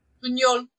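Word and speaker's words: Buñol: Bunol.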